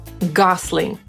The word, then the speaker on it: Gosling: Jak pewnie usłyszeliście, on sam wymawia swoje nazwisko jako Gosling przez S.